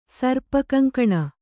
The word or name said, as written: ಸರ್ಪಕಂಕಣ